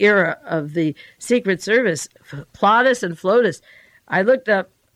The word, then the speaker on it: Plotus: I better spell it, because if I say it wrong, it's going to sound wrong. He's Plotus, P-L-O-T-U-S, and she's Flotus, F-L-O-T-U-S. I'm not going to put an A there instead of the O, but you get Plotus and Flotus is Ronald and Nancy.